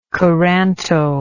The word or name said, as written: coranto